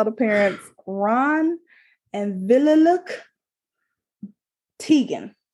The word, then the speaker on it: Teigen: The traditional way to actually say her last name is pronounced Teigen. Chrissy says that she kind of gave up on people pronouncing it that way. And she said she kind of prefers the Teigen pronunciation anyway.